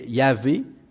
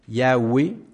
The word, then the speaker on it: Yahweh: La prononciation, c'est Yahvé, Yahweh ou Yahweh. On ne sait pas exactement, officiellement, comment ça se prononce.